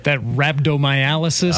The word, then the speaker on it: rhabdomyolysis: Is that rhabdomyolysis? – That's exactly what that is.